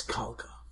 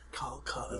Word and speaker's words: Kalka: My name is Kalka. – Kalka. – Kalka. It's spelled the way you spell it, K-A-L-K-A.